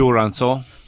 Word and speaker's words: Jurançon: What's the pronunciation? – Jurançon. – That's Jurançon, and it's...